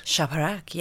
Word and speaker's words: Shaparak: Shaparak, yes.